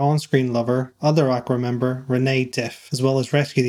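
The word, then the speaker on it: René: The video follows the adventures of Lane Nystrom, who's trying to find her on-screen lover, other Aqua member, Rain Diff. – It's Renee, actually it's Renee. It's Renee, the accent on the second E.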